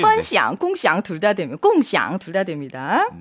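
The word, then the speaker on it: 꽁샹 (共享): 꽁이 사성이 아닌가요? 했는데요. 네, 꽁 여러분 사성 맞습니다. 네. 네, 제가 여기 필기를 일성으로 해놨는데요. 그 이유가 공유하다가 펀샹이 또 있어요. 그래서 지금 꽁샹이라고 발음했는데 꽁샹이 맞아요, 여러분. 펀샹, 꽁샹 두 가지 다 됩니다, 여러분.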